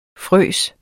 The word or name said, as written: fryser